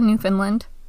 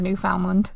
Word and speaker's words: Newfoundland: Stu and I had a whole debate the other day about whether it's Newfoundland or Newfoundland. – I thought it was Newfoundland, but also there's not an old Finland. And it's spelled found. – Yeah. – So I think I say Newfoundland. – Yeah, but you say everything wrong. – Which led to us having a discussion about how in British English you usually put the emphasis on the second syllable, not the first one.